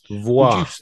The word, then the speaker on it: Voix: Well, that's the French pronunciation for voice. Uh, okay, so it's voix. I would I would say so, but there may oh. be a British alternative like Voix.